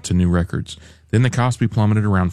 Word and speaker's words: KOSPI: The benchmark KOSPI, how do you say this, Randy? – KOSPI. – The KOSPI index more than tripled in value, driven by the faith in the AI boom.